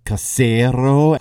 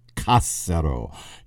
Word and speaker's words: Cassero: Some people do pronounce this Cassero, but we know from the accent stress that his name gets in other texts that it's actually pronounced del Cassero.